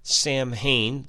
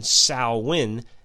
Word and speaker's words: Samhain: Halloween has its original roots in the pagan Celtic religious celebration of Samhain, which is pronounced in a variety of ways. The two I've heard most are Samhain, that's Samhain, and Samhain, Sowin.